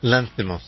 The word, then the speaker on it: Lanthimos: Poor Things is a movie directed by Yorgos Lanthimos, I think it's pronounced Lanthimos.